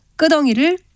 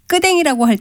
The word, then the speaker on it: 끄덩이: 발음을 편하게 하려고 끄덩이를 끄댕이라고 할 때가 있지만 이는 표준 발음으로 인정되지 않는 것입니다.